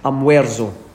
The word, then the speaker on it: almuerzo: Almuerzo.